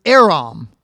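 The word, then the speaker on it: Erom (עָרוּם): Crafty in the Hebrew is the word Erom, E-R-O-M.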